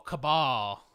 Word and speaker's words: Cabal: I've been pronouncing his name wrong my whole life. I thought it was Cable because that's how you see. You know, there's no pronunciations, but Cable is who I thought his name was pronounced. – Cable, like C-A-B-L-E. – Well, C-A-B-A-L.